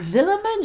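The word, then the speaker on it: Vilhjálmur: And his name was Villamer Johnson. – Villamer. – Villamer Johnson.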